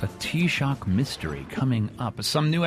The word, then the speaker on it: Taoiseach: Yes, I just learned how to pronounce T-A-O-I-S-E-A-C-H, which is not Tao is each, it's T-Shock.